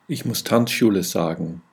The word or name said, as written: tanzschule